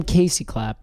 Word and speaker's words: Casey Clapp: Now, I worry when I say KC Clapp, people will think your name is like initials KC. – Oh, yeah, it's not. – But your name is Casey. – Yeah. Spelled with a C, an A, an S, an E, and a Y. I am Casey Clapp. Yes, just the normal spelled out.